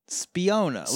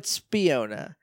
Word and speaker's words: Spiona: Spiona. Like Spiona. Fiona. – But Spiona. It means sneaky person in Italian. – Okay. – It sounds almost like a people name. Rhymes with Fiona.